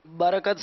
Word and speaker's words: برکت: تلفظ درست کیجئے. برکت صحیح ہے یا برکت صحیح ہے؟ – برکت صحیح ہے.